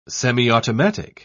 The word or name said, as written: semiautomatic